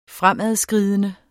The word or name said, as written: fremadskridende